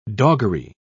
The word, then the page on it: doggery: British English